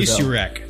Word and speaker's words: Misiurak: Misiurak. – Misiurak. It's spelled M-I-S-I-U-R-A-K.